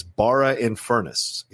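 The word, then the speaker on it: Barrow-in-Furness: It says Barra in Furness is how you say that city he is from.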